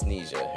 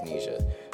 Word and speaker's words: Nija: When you see it, it's spelled like Nija, though, like N-I-J-A, but it's Neja. Her name's Neja.